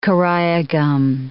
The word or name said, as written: karaya gum